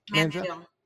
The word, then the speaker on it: Manziel: Manziel.